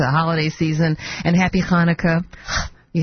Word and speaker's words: Hanukkah: We don't have that sound in English, but you do have it in a number of languages. I think Greek has it, and Hebrew does have it. Happy Hanukkah is how you say it, and Merry Christmas in advance.